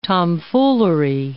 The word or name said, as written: tomfoolery